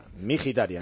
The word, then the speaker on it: Mkhitaryan: Luego, por dentro, Varela, Aslani y el impronunciable Mkhitaryan. – Igual que Zakarian, la KH es una jota. Armenio, ambos, ¿no? – Mkhitaryan, vale.